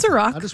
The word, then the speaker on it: Iraq: It's Iraq. – Iraq.